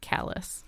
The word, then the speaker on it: Calais: So it's spelt the same, but of course in Maine, there is actually some French there, but they pronounce it Calais.